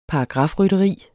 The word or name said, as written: paragrafrytteri